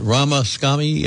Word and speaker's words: Ramaswamy: She should unite with Trump for the sake of America after she called one of the candidates, is it Ramaswamy? Is that how you pronounce it? – Yeah. – Oh, thank you. Oh, I got that one right.